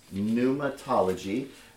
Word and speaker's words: pneumatology: And, studying pneumatology, by the way, is how you say this, pneumatology.